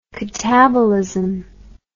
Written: katabolism

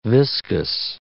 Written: viscous

